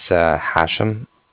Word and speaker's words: Hashem: My name is Hashem, and that's spelled H-A-S-H-E-M, as in mother at the end.